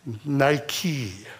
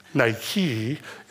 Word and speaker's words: Nike: And I'm pleased to announce that I have been right and justified, because it is Nike, not Nike. Nike is the proper way to pronounce the apparel. Okay. Not Nike, Nike.